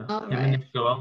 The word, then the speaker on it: Himanish Goel: Himanish Goel. Right. Yep. – Okay, so tell the people, who are you? – Yeah, so my name is Himanish Goel.